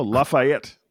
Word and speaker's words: Lafayette: Oh, Lafayette.